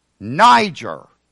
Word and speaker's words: Niger: And in case you do things phonetically, capital NY for where the emphasis goes, and J upside down ER means that the E is short. Niger, because he had a dark complexion.